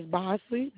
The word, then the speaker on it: Bosley: My last name is Bosley, B-O-S-L-E-Y.